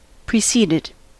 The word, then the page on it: preceded: American English